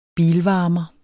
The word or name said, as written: bilvarmer